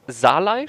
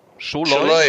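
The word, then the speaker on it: Szalai: Dafür kam, wie spricht man den aus? Salai? Schalai? – Scholoi? Scholoi. – Scholoi.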